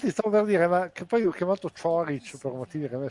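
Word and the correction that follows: Ćorić: Dovrebbe essere Cioric, lui... effettivamente, la pronuncia.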